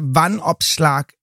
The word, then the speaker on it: Vanopslagh: Jeg ved ikke, altså Vanopslagh. Altså, jeg er nødt til at dele det op. Jeg har jo hørt det udtalt nu 10 gange, for bare at kunne sige det. Og for mig lyder det som noget med et opslag, et vandopslag.